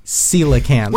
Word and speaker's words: coelacanths: Coelacanths.